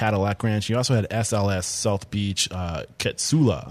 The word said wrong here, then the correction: Katsuya: Am I saying that right? Ketsula? – Katsuya, Katsuya. Katsuya Dragon Yeah.